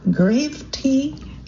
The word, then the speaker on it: Gravetye: It is Gravety, it's spelled G-R-A-V-E-T-Y-E.